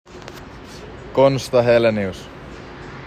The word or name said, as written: Konsta Helenius